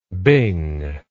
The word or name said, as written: bing